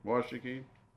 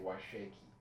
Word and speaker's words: Washakie: It's joined by Crook and Campbell, Niobrara, Natrona, Johnson, Sheridan, Washakie. – That's how you pronounce it. See, that's the important thing, because natives know how to pronounce counties. See, I would always say Washakie.